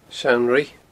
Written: Seann Ruigh

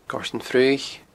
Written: Goirtean Fraoich

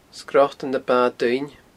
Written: Sgrotan na Ba Duinne

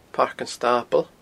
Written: Pairc an Stabaill

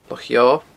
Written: Loch Eo

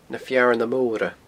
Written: Na Fearna Mora

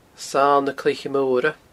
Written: Sail na Cloiche Moire

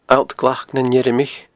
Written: alltglacnanimirichean

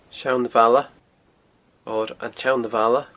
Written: Seanbhaile

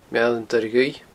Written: Meall an Doire Dhuibh

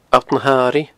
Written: alltnahairighe

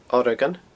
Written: Oregon

